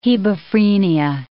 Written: hebephrenia

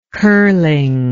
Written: curlingly

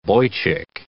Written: boychick

